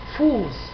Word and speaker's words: Fuß: Finalmente, o en tercer lugar, la SZ. Esta beta que nos aparece y de la que vamos a hablar muy brevemente al final de este resumen, se pronuncia como una S sorda. En realidad equivale a una doble S. Fuß, pie. La V se pronuncia casi siempre, excepto en extranjerismos, como F, Por ejemplo, Vogel, no Vogel, sino Vogel, pájaro.